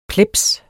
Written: plebs

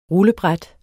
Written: rullebræt

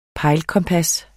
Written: pejlekompas

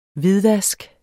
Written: hvidvask

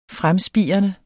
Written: fremspirende

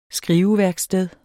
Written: skriveværksted

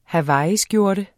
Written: hawaiiskjorte

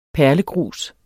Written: perlegrus

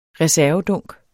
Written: reservedunk

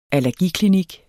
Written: allergiklinik

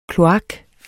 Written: kloak

